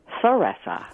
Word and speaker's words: Theresa: They say Theresa. – Yes. Theresa. – Theresa. – Yep, like a T-H for the, Theresa.